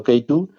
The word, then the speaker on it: Keytu: pero la hemos llamado Keitu, eh, en plan así un poco span English, eh, la pronunciación de K2 en inglés, pero bueno, escrito un poco K-E-Y-T-U.